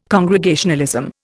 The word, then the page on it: Congregationalism: Indian